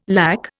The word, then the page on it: LAC: Indian